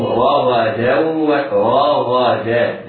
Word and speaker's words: رغدا: حيث شئتم رغدا، إياك وأن يجعل تفخيم يؤدي تفخيم الراء والغين إلى تفخيم التال فتصبح ضادا أو قريبة من الضاد، لا، احرص على ترقيق الدال بعد الحرفين المفخمين، بعد الراء والغين المفخمتين، فتنطقها تنطق هكذا، حيث شئتم رغدا ورغدا دا دا، حيث شئتم رغدا وادخلوا، رغدا وادخلوا، تنوين بعده واو حكمه الإدغام بغنة.